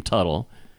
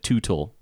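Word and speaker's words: Teutul: Anyway, I remember interviews with them and them pronouncing their name Tuttle, and this AI voice kept calling it Tootle.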